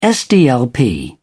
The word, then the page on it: sdlp: British English